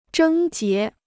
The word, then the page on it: 症结: zhēng jié